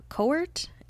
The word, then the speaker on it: Cowart: Going to the photography example, if someone wanted to get into photography, Jeremy, I think his last name is pronounced Cowart. It's C-O-W-A-R-T.